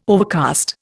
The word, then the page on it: overcast: Indian